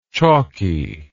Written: chalkiness

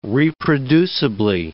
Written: reproducibly

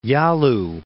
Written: Yalu

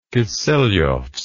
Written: kiselevsk

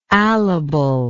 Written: alible

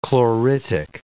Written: chloritic

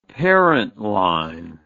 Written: parentline

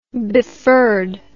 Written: befurred

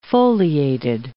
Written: foliated

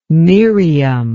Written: miriam

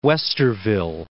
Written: Westerville

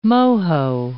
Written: Moho

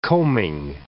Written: coaming

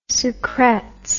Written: sucrets

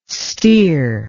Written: stere

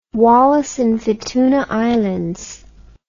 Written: Wallis and Futuna Islands

